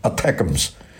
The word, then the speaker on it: ATACMS: 当然我们最近我们看见有这样的报道啊，乌克兰方面发射了美国陆军的战术导弹，叫 ATACMS 啊，叫 ATACMS，我们读成 ATACMS，